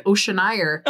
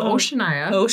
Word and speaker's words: Oceania: She listened to one that had the weirdest like pronunciation of Oceania. – Dang. – So she kept saying Oceanire. – Oceanire Twain. – Literally. – Holy smokes. – Yeah. Okay, so Oceania.